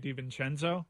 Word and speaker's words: DiVincenzo: DiVincenzo? – Excuse me.